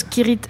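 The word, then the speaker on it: Cirith: Le col de Sirit Ungol. – C'est ça. – Je prononce Kirit Ungol, un truc comme ça.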